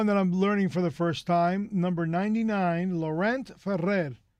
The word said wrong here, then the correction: Laurent Ferrier: Laurent Ferrier, right. – How would I say it? Laurent? – Laurent Ferrier.